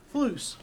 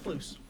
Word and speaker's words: Fluce: Fluce. – Fluce. – Yep, Fluce. – Yeah. Wasn't sure if I was pronouncing it right. – Yeah, earlier. – No, it's Fluce. My great grandfather immigrated from Italy, and the last name was Frucci, F-R-U-C-I.